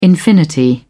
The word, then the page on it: infinity: British English